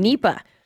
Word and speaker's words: Nipah: And I'm not sure I'm saying this right. Nipah? – Nope, you did it right the first time. – Nipah.